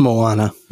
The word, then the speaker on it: Moana: you said Mona. Is that not the pronunciation? That's Moana. Moana? Oh. – Or did I spell it wrong? I don't know. No, it's, it's Moana.